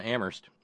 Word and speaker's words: Amherst: Meanwhile, up in Amherst, where they don't pronounce the H, Terry with a note.